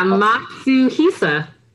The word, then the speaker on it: Matsuhisa: Like Nobu, like that's like Nobu Matsushisa, I think, how you say his name? Matsuisa, Nobu, I think. I don't want to butcher his name. – Yeah, Matsuhisa. Damn, that's mouthful. Is that how you say it? Matsuhisa.